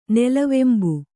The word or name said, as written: ನೆಲವೆಂಬು